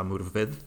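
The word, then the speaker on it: Morfydd: che immagino sarà vo- gallese, si pronuncerà Murved. – Sì, sì, gallese.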